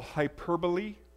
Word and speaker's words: hyperbole: There's a term called hyperbole. It's like an emphasis. Did I get that right, Jim? Okay, I said it right. Hyperbole.